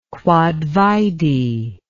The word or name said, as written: quod vide